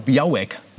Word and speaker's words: Białek: Und Bartosz Biau äh, genau, Białek wird da, glaube ich, ausgesprochen.